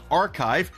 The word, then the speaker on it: arXiv: The scientific research repository called Archive, that's spelled A-R-X-I-V, has announced new rules targeting heavily AI-generated academic submissions.